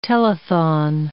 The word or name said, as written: telethon